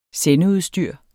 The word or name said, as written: sendeudstyr